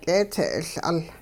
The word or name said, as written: k'eetsee'iL'ul